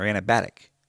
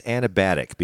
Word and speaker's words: anabatic: A-N-A-B-A-T-I-C. – So you're trusting Google? – Yeah. – Maybe it's like acrobatic. Anabatic. – Anabatic. Yeah, okay.